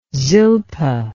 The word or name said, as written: Zilpah